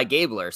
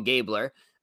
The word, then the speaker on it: Gabler: So now we have Mike Gabler, I believe is how you pronounce his last name. – Yeah. – And I believe he's going by Gabler. So yep. I'm going to call him Gabler.